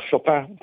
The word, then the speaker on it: Chopin: a Copin o Chopin, che poi dopo si voglia dire con accento francese, ma a Schubert.